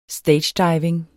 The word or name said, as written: stagediving